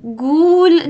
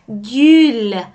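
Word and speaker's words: gül: فاره، فاره، گل، گل، گول نیست ها بچه ها، گول نیست، گل هستش، او تلفظ میشه، گل.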